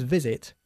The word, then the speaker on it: visit: We usually use visit when we're talking about visiting people, okay? Y por cierto, se pronuncia con una V.